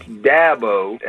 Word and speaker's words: Dabo: It's Dabo, as in apple, Dabo.